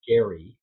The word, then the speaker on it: Gerry: So it was named after this guy named Jerry, G-E-R-R-Y. It's actually pronounced Gary.